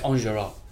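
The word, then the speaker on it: Enjolras: He's E-N-J-O-L-R-A-S, and it's pronounced Anjurus.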